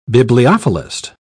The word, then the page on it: bibliophilist: British English